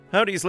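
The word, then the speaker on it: Hovvdy: Although the W is spelt with a double V, so Havd-V-D, but Howdy is how you pronounce it.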